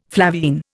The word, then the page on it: flavine: Indian